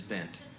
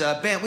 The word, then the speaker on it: Bengt: It is Bent. It's B-E-N-G-T, Swedish name. Swedish, because I am from Utah. I know, doesn't make sense. It is Bent. We say Bent. In Sweden, you would actually pronounce the G.